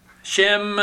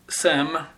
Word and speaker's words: Shem: Shem is, that's the way it's spelled and pronounced usually in English. But I do believe you can drop the H and just simply have Sem, because he is a, this is the Semites.